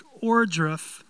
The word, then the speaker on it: Ohrdruf: In World War II, the first town in which the Allies discovered a concentration camp was the town, I believe it's pronounced Ohrdruf, Germany.